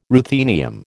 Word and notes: ruthenium: American English

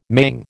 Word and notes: maying: American English

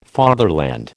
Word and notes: fatherland: American English

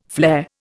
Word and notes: flare: Indian

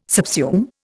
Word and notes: subsume: Indian

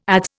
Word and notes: adze: Indian

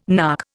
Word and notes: nark: Indian

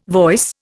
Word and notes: voice: Indian

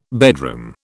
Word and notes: bedroom: British English

